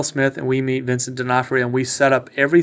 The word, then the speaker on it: D'Onofrio: Is that how we say that right? D'Onofrio? Mm-hmm.